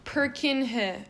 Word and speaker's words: Purkinje: But anyways, Purkinje fibers, I need to know how to say this, spelled P-U-R-K-I-N-J-E.